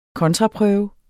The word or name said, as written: kontraprøve